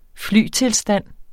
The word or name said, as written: flytilstand